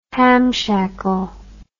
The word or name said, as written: hamshackle